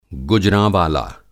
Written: गुजराँवाला

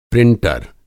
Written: प्रिंटर

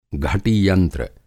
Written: घटीयंत्र

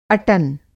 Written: अटन

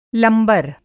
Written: लंबर

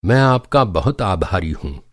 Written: आभारी

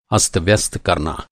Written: अस्तव्यस्त

